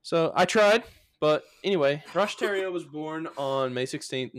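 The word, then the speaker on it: Roch Thériault: But the Ant Hill Kids cult was under the leadership of Rosh Theriault, which is spelled like Thoreau or Theriault, and Rosh is spelled like R-O-C-H. So it's a little confusing. But it's like T-H-E with an accent, R-I-A-U-L-T.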